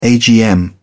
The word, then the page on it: agm: British English